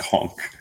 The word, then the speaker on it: conch: No, conk. – But there's an N in it. – He said the N. – And it's spelled C-H. – C-O-N-C-H is pronounced conk.